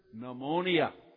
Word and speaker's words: pneumonia: and you would immediately see the word pneumonia. Pneumonia. What's the first letter of the word pneumonia? The first letter. Is it N? No. The English language doesn't pronounce the P. Pneumonia starts with P.